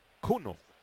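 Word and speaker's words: Kuhnel: Joel de Joel, Joel, y con K. Cunel se escribe y se pronuncia Cuno.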